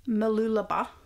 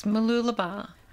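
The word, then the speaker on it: Mooloolaba: Malulaba. – Malulaba. – Yeah. – Yes, Malulaba.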